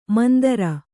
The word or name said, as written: ಮಂದರ